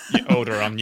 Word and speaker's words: Onderon: on landing on Onderon. I hope I've said that correctly. – Onderon, yeah.